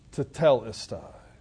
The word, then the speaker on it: tetelestai: Once his lips are moistened, Jesus says what is perhaps the most significant of all the phrases on the cross, tetelestai, properly pronounced, tetelestai.